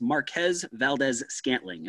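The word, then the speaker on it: Marquez Valdes-Scantling: And so, correct me if I'm wrong, it's Marquez Valdez-Scantling.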